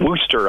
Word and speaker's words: Worcester: It seems like it, and I think they call it Worcester up there in Massachusetts.